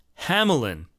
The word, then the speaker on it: Hamelin: But Russ has discovered a video of Marc-Andre himself pronouncing his name as Hamelin.